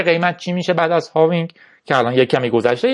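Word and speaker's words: halving: هاوینگ اتفاق مهمی هالوینگ قدیما بهش میگفتیم ولی فهمیدیم که الش تلفظ نمیشه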